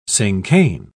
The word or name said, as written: cinquain